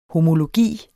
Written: homologi

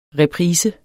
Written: reprise

